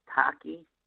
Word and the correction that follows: Haikal: Oh, no, it's, I know what you're talking about. Hey. Okay. Haikal, like H-A-I, Haikal.